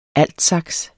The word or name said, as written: altsax